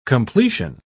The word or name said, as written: completion